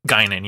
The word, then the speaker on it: Guinan: is it pronounced Guinan? – Guinan, yeah.